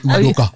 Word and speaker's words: Udoka: Amy, you can't say his name like that. Cause I can say it right. – Oh. – Why? – Cause you're Nigerian. – 28% Nigerian. Udoka.